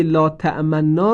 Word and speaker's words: لا تأمنا: اشمام نون مشدد در کلمه لا تأمنا این گونه است: هنگام تلفظ نون مشدد، لبها حالت تلفظ ذمه به خود می گیرد.